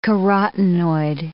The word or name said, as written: carotinoid